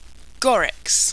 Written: gorix

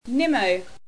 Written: Nimmo

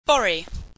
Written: bori

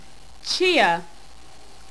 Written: chia

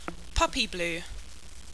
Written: Puppyblew

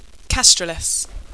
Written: kastraliss

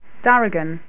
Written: darigan

